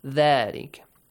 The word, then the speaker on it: ذَلِكَ: Pay attention to the ذَال, Hassan. Yes? How do you say that? ذَلِكَ. Bite the tongue a little bit. ذَلِكَ.